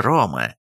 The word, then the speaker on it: Рома: Мы называем этот город Римом, но его исконное название – Рома, с ударением на первый слог.